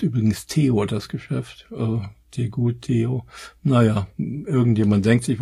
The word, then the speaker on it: Tegut: Es gibt noch einen, und zwar, weiß ich gar nicht, wie der ausgesprochen wird, Tegut. Wie T, aber nur mit einem E und dann gut wie schlecht, Tegut.